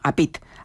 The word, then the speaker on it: APIT: APIT, que tenemos que, vamos a decir que se escribe APIT, ¿no? APIT. Así. – Eso es, sí, sí, como suena, terminado en T.